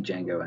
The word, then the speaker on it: Django: Django Holy Unchained. God. It looks so awesome. Seriously, people, if you haven't seen the trailer for Django Unchained, go watch it now. – The D is silent.